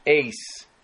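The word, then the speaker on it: eis (εἰς): Esto es muy interesante en su traducción literal del griego, la cual dice que la palabra traducida como hacia es pronunciada eis, E-I-S, y puede significar hacia, dirección, y puede significar envolverse parte de.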